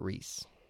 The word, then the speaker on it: Ruiz: She got married to a dude whose last name is R-U-I-Z. – Ruiz. – You would think so. – Right. And he called himself Ruiz? – Everyone else would think so. – What's he call himself? – They all pronounce it Reese. – No. R-E-E-S-E-R-E-E-C-E.